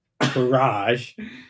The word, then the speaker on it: Courage: It's pronounced Courage, first of all. – Okay, fine. Courage.